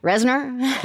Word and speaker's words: Reznor: Reznor.